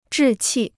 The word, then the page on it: 志气: zhì qì